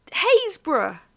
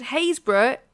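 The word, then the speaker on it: Happisburgh: I'm going to say this is Happisburg. – Haysborough. Yeah. – Said Haysborough, still the best one to pronounce. That's one of those things where if you're not from Norfolk, no one pronounces that correctly.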